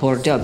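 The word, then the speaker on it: Bordò: So it's not Bordeaux, you know, in France, but it's called Bordeaux, B-O-R-D-O with an accent.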